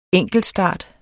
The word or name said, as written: enkeltstart